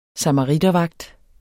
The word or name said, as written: samaritervagt